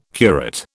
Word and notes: curate: British English

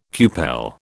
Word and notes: cupel: British English

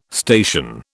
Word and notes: station: British English

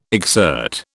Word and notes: exsert: British English

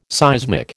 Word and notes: seismic: American English

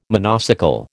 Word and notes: monocycle: American English

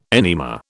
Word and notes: enema: British English

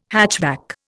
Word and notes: hatchback: Indian

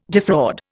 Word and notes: defraud: Indian

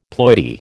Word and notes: ploidy: American English

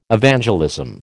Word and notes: Evangelism: American English

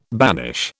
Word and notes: banish: British English

